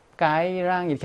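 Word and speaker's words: Cái Răng: 자, 이것이 남부 지역에서는 르 발음이 살아나요. 그래서 까이랑이라고 나와요. 우리 북부 지역에서는 즈야, 즈. 그런데 여기서 왜 까이랑이라고 했나? 까이랑이라고 하지 않고 이것의 위치가 남부에 있기 때문에 고유명사화 돼 버렸어. 이것이. 그래서 그냥 까이랑이라고 한 거야.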